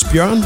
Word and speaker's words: Bjorn: I don't know if they're just missing the line in it, so it's Bjorn, but it's spelled out B-J-O-R-N.